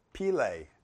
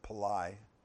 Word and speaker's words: Pillai: Years ago, there was a man who came to the United States from India, Bishop K.C. Pillai, is the way he pronounced it. We always said Pillai.